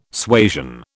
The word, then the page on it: suasion: British English